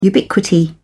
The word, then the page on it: ubiquity: British English